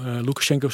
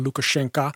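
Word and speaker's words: Lukashenka: Loekasjenko is overigens de Russische uitspraak. Hè. Eigenlijk moet je zeggen Loekasjenka. Als je het een Belarus of een Wit-Rusland hierover spreekt.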